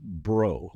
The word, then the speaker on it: Breaux: Now, my name is spelled B-R-E-A-U-X. And as any good Cajun would know, it is pronounced bro, like B-R-O.